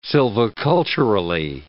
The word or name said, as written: silviculturally